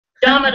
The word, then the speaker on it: Domet: This is Jonathan Puga with another Get to the Point, and I'm super excited today to have Angela Domet on the show, or Domet. I hope I'm saying that right. – Domet will work.